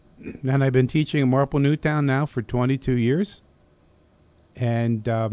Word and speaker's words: Marple Newtown: But history teacher from, is it Marple Newtown? Am I saying that right? – Marple Newtown. – Marple Newtown.